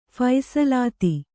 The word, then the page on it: ಫೈಸಲಾತಿ: phaisalāti